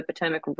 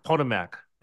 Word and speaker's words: Potomac: I live near the Potomac River. So love. – That's how you say it. Not Potomac. It's Potomac.